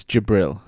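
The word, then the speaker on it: Djibril: It's Jibril. – Jibril. All right, Lord Jibril. – The D is silent, Brian.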